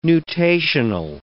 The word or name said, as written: nutational